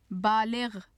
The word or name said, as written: baligh